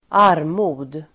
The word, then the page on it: armod: [²'ar:mo:d]